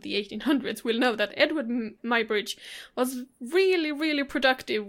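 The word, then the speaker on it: Eadweard: Yeah, I believe it's just pronounced Edward, but it's not spelled the way Edward usually is.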